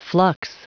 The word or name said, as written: flux